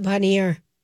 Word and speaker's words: Boner Lake: But they call it Bonnier. – Bonnier. – It's Bonnier. It's spelled like Boner.